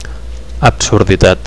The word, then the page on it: absurditat: Catalan